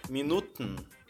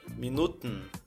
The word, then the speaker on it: Minuten: Minutos é Minuten. Se escreve Minuten, mas você engole o E. Minuten. É só travar a língua aqui no dente e falar minuten, minuten. Como você diz então, você tem alguns minutos?